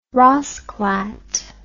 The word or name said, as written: raasclat